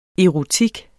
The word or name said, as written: erotik